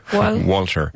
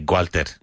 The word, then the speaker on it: Walter: Walter. – Walter. But many Spanish people say Gualter.